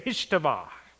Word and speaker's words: Hishtavah: Everybody say Hishtavah. Ah, very good. Very good. Hishtavah. I heard somebody say, ah. That's right. If you spit on your neighbor, you said it right.